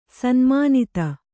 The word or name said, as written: ಸನ್ಮಾನಿತ